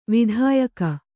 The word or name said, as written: ವಿಧಾಯಕ